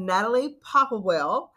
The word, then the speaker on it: Natalie Popplewell: Today on the show, I have Natalie Popplewell, and I think I said your name right this time.